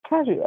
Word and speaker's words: Cajou: Because it sounds like cashew. – Right. It's not that hard, but okay. Cajou. We've heard Cajou, like, literally, we've heard all these names. – Yeah, Cajou Creamery here.